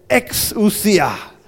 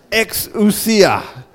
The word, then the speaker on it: exousia: The original word is the word exousia. Can you say that? Exousia. Turn to the person beside you and say, exousia. I want you to remember this word. Exousia.